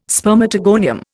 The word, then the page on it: spermatogonium: Indian